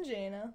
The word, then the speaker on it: Jaina: Oh, Jason, Jaina. Well, it's spelled not like regular Jason. It's spelled like space Jason.